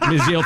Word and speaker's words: Menzel: Well, it's pronounced when I mentioned that, Menzel, thank you.